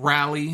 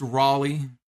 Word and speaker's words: Raleigh: It's not Rally, it's Raleigh.